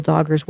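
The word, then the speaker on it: Dagher: It's D-A-G-H-E-R. And, of course, I never asked you if I was pronouncing it right, but I think I am. – Pretty close, yes.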